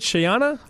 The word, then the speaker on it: Scheana: Am I saying that right? Shayana? – I was going to say that's the hardest part of this whole story. Is that she? I think you got it. – S-C-H-E-A-N-A.